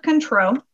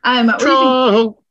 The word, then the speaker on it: Cointreau: We used 1 ounce of Control. I hope I'm saying that right. My French skills are no es bueno. – How's it spelled? – Cointreau. – Control? – Yeah, that's... – Yeah, it sounds, yeah. Sounds right.